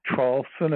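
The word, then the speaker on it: Traulsen: My name is Hugh, H-U-G-H. The last name, Trawlson, is spelled T like Tom, R-A-U-L-S-E-N like Nancy.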